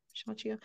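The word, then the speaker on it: Xianxia: I like Shanxia. Am I saying it right? Shanxia.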